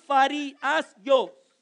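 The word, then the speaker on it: Pharisios (Φαρισαῖος): Pharisee, it's pronounced Pharisios. Pharisios, another Greek word.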